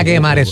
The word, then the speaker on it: Guemare: Andrea Guemare Soto. – Guemare, es que se pronuncia. Eni, si tuviese los dos puntitos, sería Guemare. – Ah, bueno. – Pero como no tiene los dos puntitos, es Guemare.